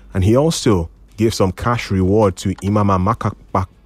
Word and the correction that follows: Amapakabo: Wow, I was going to start out the name at least once here. Amapakabo, that's how it's pronounced.